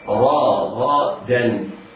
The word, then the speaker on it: رغدا: اسمع مرة ثانية، رغدا. الراء راء مفتوحة حكمها التفخيم، والغين مفتوحة حكمها التفخيم، بعدها الدال من حروف الاستفال فيكون حكمها التلقيق. هكذا